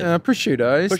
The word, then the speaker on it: prosciutto: Prosciutto.